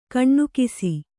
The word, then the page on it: ಕನಿಕಿಸು: Kannada